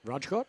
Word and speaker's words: Rajkot: Have I pronounced that correctly? Rajkot? – Yep.